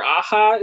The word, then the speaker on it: Aja: I think it's Alexander Aja is how you say his last name.